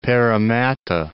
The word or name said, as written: Parramatta